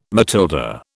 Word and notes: Matilda: British English

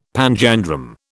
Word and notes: panjandrum: British English

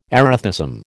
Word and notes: erethism: American English

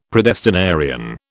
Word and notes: predestinarian: British English

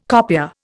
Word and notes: copier: Indian

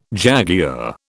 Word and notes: jaguar: British English

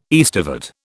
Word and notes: aestivate: British English

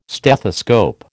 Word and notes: stethoscope: American English

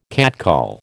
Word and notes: catcall: American English

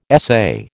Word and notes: essay: American English

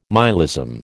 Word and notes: myalism: American English

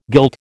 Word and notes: guilt: American English

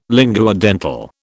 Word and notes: linguodental: British English

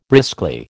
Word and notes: briskly: American English

